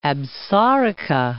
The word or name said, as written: Absaroka Range